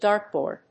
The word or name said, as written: dartboard